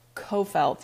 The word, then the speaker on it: Coffelt: I've gotten Coffelt. I've gotten, what else have I gotten? Coffelet. My students call me Miss Coffee because they're like, yeah, Coffelet.